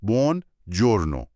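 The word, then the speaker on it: buongiorno: به نظر ساده میاد، بونجورنو.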